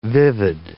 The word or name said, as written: vivid